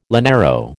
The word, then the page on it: llanero: American English